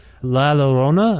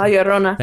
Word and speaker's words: La Llorona: Then there are pieces like The Crack Where the Light Gets In and your version of the Mexican folk song La Llorona, I'm sorry if I mispronounced it. La Llorona.